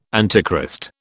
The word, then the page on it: Antichrist: British English